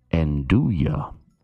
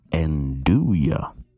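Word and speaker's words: 'nduja: The correct pronunciation is Andouille. Andouille.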